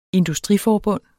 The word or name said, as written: industriforbund